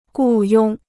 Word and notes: 雇佣: gù yōng

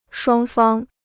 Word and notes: 双方: shuāng fāng

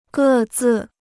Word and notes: 各自: gè zì

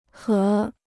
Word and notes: 合: gě

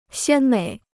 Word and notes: 鲜美: xiān měi

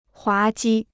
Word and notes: 滑稽: huá jī

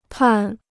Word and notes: 判: pàn

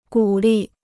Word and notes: 鼓励: gǔ lì

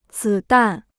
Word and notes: 子弹: zǐ dàn

